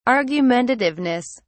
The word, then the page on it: argumentativeness: British English